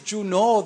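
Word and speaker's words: Junot: My name, in fact, is Juno. The T is silent. If you pronounce the T, you're pronouncing my name wrong.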